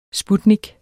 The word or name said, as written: sputnik